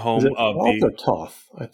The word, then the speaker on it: Thoth: From the future here to tell you that apparently the most common pronunciation is Thoth.